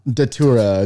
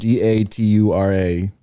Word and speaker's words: Datura: Datura is how you say it. Datura.